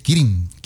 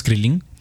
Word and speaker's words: Kirin: ¿Cómo se dice? Kirin. – Kirin. – Kirin. ¿No es Krilin? – No, Copa Kirin.